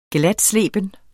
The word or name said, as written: glatsleben